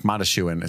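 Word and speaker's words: Mondoshawan: Yeah, it's like Mondashawan is how they pronounce it, different than it looks.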